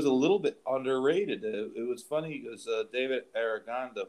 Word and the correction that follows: Aragona: who does the – that's not his pronounced name, right? Pronounce name, right? – Aragona, right? – Yeah.